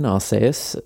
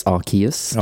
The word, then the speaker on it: Arceus: If you want the Japanese pronunciation, Arceus, the English pronunciation, apparently it's Arceus. – Arceus. – Either of those is fine.